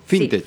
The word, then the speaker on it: Fintech: La norma busca facilitar la renegociación de créditos de familias y empresas con entidades bancarias, financieras y Fitnet. ¿Se dice así? – Fintech. – Sí, Fintech. Ahí está, me trabé.